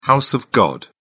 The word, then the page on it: house of god: British English